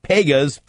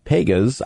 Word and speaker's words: Pegahs: If you're looking for a nice hot lunch today, come and join me at Pegas, P-E-G-A-H-S, is how you spell it. Pegas, I will be at the location off of 87th Street in Lenexa, just two blocks west of 87th and Quivira.